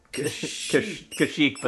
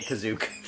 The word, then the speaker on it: Kashyyyk: Kashyyyk, but they're pronouncing it Kazook.